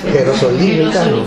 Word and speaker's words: jerosolimitano: Jerosolomitano. – Jerosolimitano. Yo creo que es jerosolimitano.